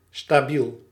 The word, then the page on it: stabiel: /staˈbil/; /sta.bi:l/